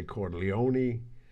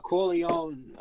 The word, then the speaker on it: Corleone: The Corleone, without the pronunciation of the E, is the bastardized American version.